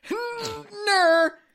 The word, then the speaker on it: Knurr: it's a silent K, but the N, the HN'NR. So it's. Knurr. You got to get like a little gap in there too.